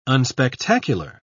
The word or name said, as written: unspectacular